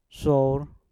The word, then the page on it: sour: mpur